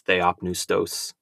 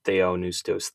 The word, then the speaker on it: theopneustos: And actually, I mispronounced that Greek word. It's uh, I think it's theopneustos, not theonoustos, theopneustos, if I remember correctly.